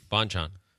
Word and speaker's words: Bonchon: Bonchon? – Bonchon.